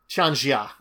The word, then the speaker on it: Tianjia: Say you're traveling along, you could meet a merchant from far off Tianjia? – Tianjia, I think it is. Tianjia, it's kind of the Chinese pronunciation.